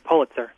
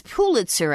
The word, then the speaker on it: Pulitzer: I love the program, but I think it's pronounced Pulitzer. The two items... – Yeah, I think it's Pulitzer, actually. Pulitzer. Somebody look it up for us, okay? Because I hear it pronounced all the time.